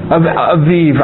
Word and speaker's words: Abib: Now, let me, I don't know where it is, the word Abib. I thought it was, it's what? – Aviv, I'm sorry. A-B-I-B, the B is pronounced like a V, yeah. I don't do Hebrew pronunciations too good. A-B-I-B, okay?